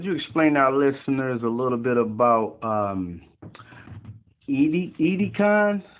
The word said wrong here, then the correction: Ideagen: Hollywood? I don't know if I'm saying that right. Edecon Hollywood. – You mean Ideagen? – Oh, okay, yeah.